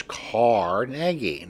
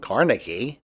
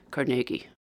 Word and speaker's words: Carnegie: But this is Carnegie, not Carnegie. – Linguistics expert Barbara Johnstone says it's tricky to talk about correct pronunciation of proper nouns, but it is helpful to know how the name's owner may have said it. – He would have pronounced it Carnegie.